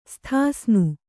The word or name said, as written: ಸ್ಥಾಸ್ನು